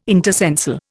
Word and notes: intercensal: Indian

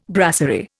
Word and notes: brasserie: Indian

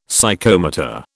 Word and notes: Psychomotor: British English

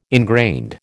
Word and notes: ingrained: American English

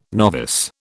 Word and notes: novice: British English

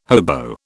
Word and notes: hobo: British English

